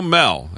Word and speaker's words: Moell: Well, our guest has been Joe Mell, and his last name is spelled M-O-E-L-L. The O is silent.